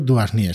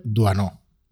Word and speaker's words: Doisneau: Robert mm, Doisneau no se pronuncia, no se pronuncia ni un españolizado Robert, Robert Duasnier, sino más bien Robert Duanó.